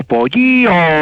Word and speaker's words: Poyio: P-O-Y-I-O, pero es con mucha I y con mucha O. Pollío. – Como el dedo meñique. – Le estoy preguntando a un colombiano qué significa pollío.